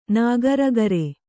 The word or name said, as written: ನಾಗರಗರೆ